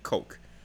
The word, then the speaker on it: Koch: 那在一九二零年的时候，就叫 Erhard Koch，就是 K O C H，但是他英文念成 Coke，就是可乐那个 Coke。